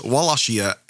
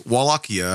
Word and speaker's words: Wallachia: I tried to pronounce it, but it's Wallachia, I guess. It's Wallachia.